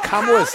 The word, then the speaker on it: Kamala: So Kamala's how do you niece. Pronounce it? – First you say comma like a comma in a sentence. Then you say la like la la la la la.